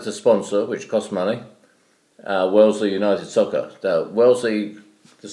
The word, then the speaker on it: Wellesley: Wellesley. I said that right? – Yeah,